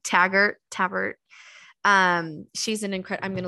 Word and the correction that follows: Teichert: Okay. It's Teichert. – Okay. – Tykert, Minerva Tykert.